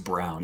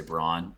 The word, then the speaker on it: Brown: And Brown, I actually think it's pronounced Brown, even like everything in your brain is telling you to say Braun.